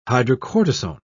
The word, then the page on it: hydrocortisone: British English